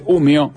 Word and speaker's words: Umeå: Yo le decía al Soco que me escribieron desde Umeå. – Sí, bien, lo dije bien. Pero se escribe Umeå. – Sí, pero es una A que seguramente tiene un redondelito arriba y suena como una O.